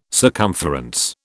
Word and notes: circumference: British English

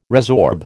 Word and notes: resorb: American English

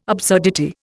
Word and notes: absurdity: Indian

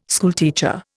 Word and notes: schoolteacher: Indian

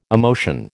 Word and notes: emotion: American English

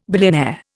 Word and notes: billionaire: Indian

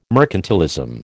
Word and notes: mercantilism: American English

